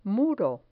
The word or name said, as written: muro